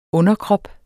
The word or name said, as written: underkrop